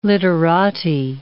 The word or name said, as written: literati